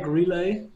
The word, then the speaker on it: Relai: Is it Relai or Relai? – Oh, everybody has his own version. I like Relai, but some people say Relai. It probably depends where you come from.